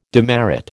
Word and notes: demerit: American English

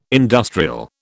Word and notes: industrial: British English

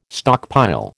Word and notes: stockpile: American English

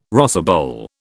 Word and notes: rosebowl: British English

